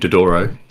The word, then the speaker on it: Dodoro: I can't pronounce his last name. – Adrian Dodoro. – Dodoro, yeah. That's the one.